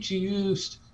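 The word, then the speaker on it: Juste: He has a T in his name. – It's not pronounced. – Oh, is he Haitian? Just like Kevin Pierre LaPaul, what's his name last year? Pierre Lewis. Lewis. No, I heard this. I heard that the pronunciation guide from the team says that it's supposed to be pronounced juice. – Juice.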